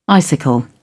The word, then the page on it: icicle: British English